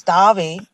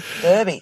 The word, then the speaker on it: Derby: Well, in the UK pronunciation, it's Derby. Sorry. And in American, it's Derby.